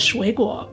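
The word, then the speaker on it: shui guo (水果): Kareem's word was fruit, which, based on the strange phonetics, he pronounced shui gu. It's actually pronounced shui guo, but, you know, shui gu.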